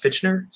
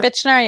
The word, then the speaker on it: Fichtner: Kent, who's played by William, how do you say his last? Fichtner? Something like that? – Fichtner, yeah, I think.